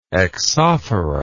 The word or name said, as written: exophoric